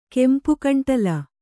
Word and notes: ಕೆಂಪುಕಂಟಲ: kannada

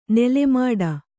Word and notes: ನಾಲ್ಮಡಿ: nālmaḍi

